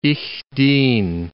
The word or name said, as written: ich dien